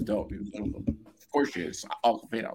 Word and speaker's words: Awkwafina: By the way, what I also really liked about that movie was Awkwafina. I don't know if I'm pronouncing it right. – Yeah, you're saying her name right.